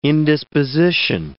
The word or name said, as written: indisposition